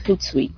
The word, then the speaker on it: Hootsuite: I thought Hootsuite, but hmm, is that how you say it? Hootsuite? – Yes, Hootsuite.